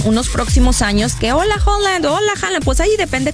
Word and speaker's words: Haaland: Pues no, sí, justamente que muchos les dicen Haaland, pero ya nos dijeron que se pronuncia Haaland.